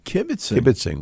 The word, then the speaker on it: kibitzing: Kibitzing with people.